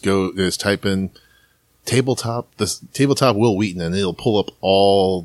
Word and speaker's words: Wheaton: And you can see the progression of Will Wheaton. As he progresses. Like, that is the correct pronunciation, guys. I don't want to hear otherwise.